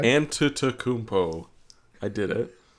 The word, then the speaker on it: Antetokounmpo: Dang it. It's 5 syllables. Antetokounmpo. – Okay, now say it fast. – Antetokounmpo.